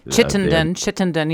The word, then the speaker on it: Chittenden: Now, the Lake Washington level was kept constant by an outflow through the locks, the Chittenden locks, however you pronounce it. – Chittenden, yes, that's right.